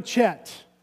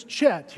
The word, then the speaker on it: Chet: I have been called Chad, Chat, Jet, Jeb, Jet, Brett, but never Chet. When I go into a restaurant, they say, "What's your name?" I say, "My name is Chet. Here's how it's spelled, C-H-E-T.